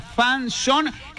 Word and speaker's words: fanzone: Atención, palabra fanzón, que se escribe fanzone con Z, F-A-N-Z-O-N-E.